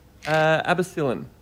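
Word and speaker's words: Abbocillin: Abacillin? – Abacillin.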